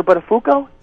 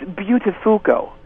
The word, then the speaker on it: Buttafuoco: He's changed the pronunciation of it. It's no longer Buttafuoco. – Yeah? – It's Buttafuoco.